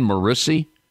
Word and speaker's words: Morrissey: Max and Ben Morrissey.